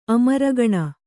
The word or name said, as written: ಅಮರಗಣ